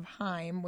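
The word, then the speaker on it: Haim: It's a group of sisters, and they go by the name of Heim, which there's a lot of discrepancy on how to pronounce that, so I think we've got it right. We read somewhere that it rhymes with time per their manager spokesperson.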